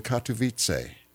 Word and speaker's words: Katowice: I've lost contact in Katowice. – Oh, yes. – Did I pronounce that sort of okay? – Yes, that's very good pronunciation.